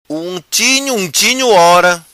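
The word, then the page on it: Unchinho Unchinho Ora: Macanese Patuá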